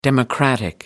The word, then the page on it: democratic: American English